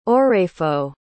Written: oraefo

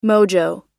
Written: mojo

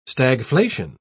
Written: stagflation

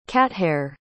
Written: cathair

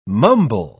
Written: mumble

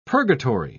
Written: purgatory

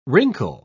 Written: wrinkle

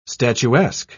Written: statuesque

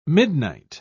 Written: midnight

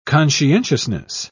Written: conscientiousness

